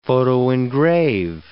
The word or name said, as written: photoengrave